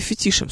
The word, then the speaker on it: фетиш: А, с феноменом и фетишем, собственно, то, о чем я сейчас говорила. Вот фетиш как раз имеет французское происхождение, поэтому у него ударение на последний слог.